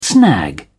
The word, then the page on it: snag: British English